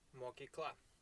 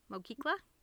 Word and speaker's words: mokykla: How do you say school? – Mokykla. – Mokykla? – Yeah, you got it.